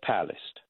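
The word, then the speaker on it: Palast: But the first thing I want to do, Carl, is you had a guest on your show yesterday, Greg Pallast. Is that correctly how you say his name? – Yeah, that's correct.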